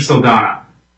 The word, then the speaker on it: Saldana: I pronounce your name correctly? – Almost. It was Mr. Saldana. – Okay, that's silent H.